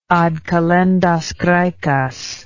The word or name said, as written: ad kalendas graecas